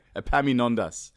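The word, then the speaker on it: Epaminondas: Apamimendos. No? Apaminondas. There we go. Apaminondas.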